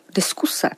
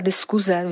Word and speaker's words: diskuse: Takže v případě slov diskuse, stejně tak jako disertace, renesance, resort nebo režisér se jako korektní doporučuje psát i vyslovovat S, ale v řadě případů najdeme u těchto slov, že se nejen ta diskuze vyslovuje se Z, ale dokonce se i píše.